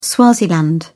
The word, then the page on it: swaziland: British English